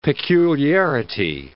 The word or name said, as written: peculiarity